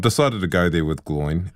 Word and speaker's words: Gloin: Yeah, because you were Gloin in The Hobbit. Did I pronounce that right? – You did.